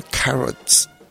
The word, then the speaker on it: carats: Hay una cosa, en los quilates del oro, en inglés se dicen carats, se escribe C-A-R-A-T, entonces algo, una pieza de oro que es de 18 quilates, es 18 carats, y carats de zanahorias, pues se escribe con C-A-R-O-T-S, pero suena muy similar, 18 carats, 18 carats, y juega con este título, 18 carats for rabbit, original del señor Jerry Mulligan.